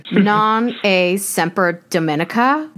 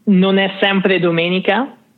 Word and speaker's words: non è sempre domenica: My favorite is, and I'm going to butcher the pronunciation, non a semper Domenica. What does that mean? And did I really kill the pronunciation? – So non è sempre domenica.